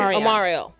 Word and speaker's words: Omarion: Omarion.